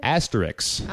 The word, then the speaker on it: Asterix: What is it? Asterix? – Asterix. Ah. Yeah, it's not like, spelled like asterisks. – Yes, it is, isn't it? – I think that's missing the S at the end.